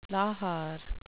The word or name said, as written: lahar